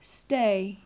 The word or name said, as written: stay